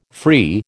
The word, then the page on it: free: American English